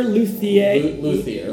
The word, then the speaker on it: luthier: Luthier? – Luthier.